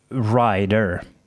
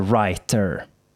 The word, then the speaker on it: writer: en sista grej som man ska göra, eh, det är att man uttalar sina T tydligt. På okay. engelska, i amerikanska engelskan så säger man ofta eh, rider istället för writer.